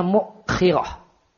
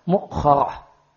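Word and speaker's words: mukhirah (مؤخرة): Nawawi mengatakan mukhirah cara bacanya mimnya di dhamma. Ya, wa kasil kha, kha-nya di kasrah. Mukhirah. Wa hamzah dan ada hamzah yang dibaca sukun. Dan ada yang mengatakan kha-nya dibaca fathah, mukhirah.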